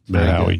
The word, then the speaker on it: Mathowie: It's just M-A-T-H-O-W-I-E, which is phonetically how you say my name, Matt Howie.